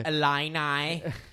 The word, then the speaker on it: Illini: And they now go on to face Blackouts, Fighting Illini. – Say it properly, please. – Fighting Illini. – Illini.